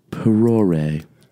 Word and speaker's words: perore: It's from Latin origin, and the word is perore. – Ooh, good pronunciation.